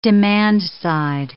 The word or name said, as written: demand-side